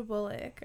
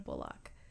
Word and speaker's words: Bullock: And Sandra Bullock are my two favorites. I recently learned that her name is pronounced Bullock. I always used to say Sandra Bullock.